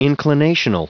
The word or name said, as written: inclinational